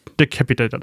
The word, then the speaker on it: Decapitator: wie heißt er nochmal ausgesprochen? Die Cap Capitator? Decapitator, Dankeschön.